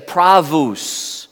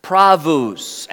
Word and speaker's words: pravus: The W, it's pronounced like a W, but it's really a V. We could say pravus, pravus.